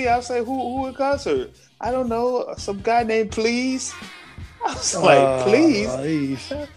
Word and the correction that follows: Plies: I said, spell it. P-L-I-E-S. I said, oh, Plies? – Plies.